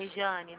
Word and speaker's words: Rejane: Regiane, seu nome se escreve normalmente, né? É Com J, J-A. – R-E-J-A-N-E. – Isso. – Regiane, né? – Isso.